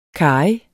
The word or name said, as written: karry